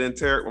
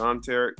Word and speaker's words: Enterick: Yeah, it's, I pronounce it Enterick, my mom pronounced it Enterick.